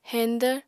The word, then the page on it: heñdvr: apatani